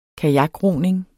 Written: kajakroning

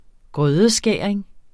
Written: grødeskæring

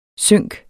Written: sync

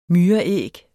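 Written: MYREÆG

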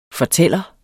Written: fortæller

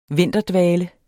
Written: VINTERDVALEN

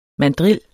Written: mandril

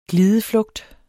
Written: glideflugt

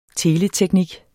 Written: teleteknik

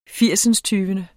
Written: firsindstyvende

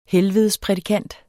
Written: helvedesprædikant

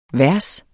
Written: vers

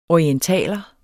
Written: orientaler

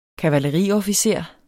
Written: kavaleriofficer

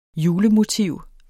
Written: julemotiv

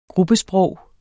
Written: gruppesprog

